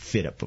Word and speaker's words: FYDP: They call it the Future Year Defense Program, an acronym FYDP, which is pronounced FITIP, of course.